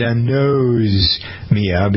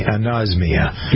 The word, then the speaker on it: anosmia: I did anosmia because it seemed like it ought to pertain to the nose, right? – Mm-hmm. Yes. – But you don't pronounce it that way. Okay, anosmia.